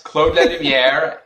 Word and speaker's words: Claude Lalumière: Since I write in English, I doubt I would have chosen a pen name that many Anglophones cannot pronounce. So yes, Claude Lalumière is my name.